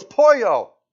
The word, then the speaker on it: Pollo: So we drive by a chicken shop, And I go, Polo. He goes, no, it's Pollo. Then don't tell me to pronounce every letter.